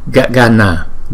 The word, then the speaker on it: Gagana: Gagana, Gagana, Gagana. Ba chữ A này không có dấu gạch ngang, chúng ta phải đọc nó một nhịp hết. Gagana, Gagana.